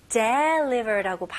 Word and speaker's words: deliver: Deliver라고 발음하는 경우가 거의 없습니다. 오케이? 다시 해볼게요. Deliver. Deliver. 오케이? 강세는 le에 해주시면 좋고요. Deliver.